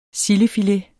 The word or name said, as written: sildefilet